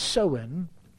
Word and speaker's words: Samhain: It originated with the ancient Celtic or Celtic festival called Samhain, and it's spelled Samhain, but it's pronounced as Samhain, when people would light bonfires and they would wear costumes to ward off ghosts.